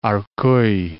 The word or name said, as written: Arcueil